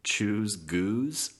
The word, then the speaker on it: Choose Goose: I think Finn pronounces it Choose Goose. Like the goose is not with an S. It's like he takes the Z sound from choose, and I kind of like it.